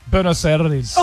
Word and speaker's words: Buenos Aires: Buenos Aires. – Oh. Buenos Aires, yeah, that's better. – Yeah. You sound a bit tongue-tied. But how would you say it? Because you're Bogan. – Buenos Aires.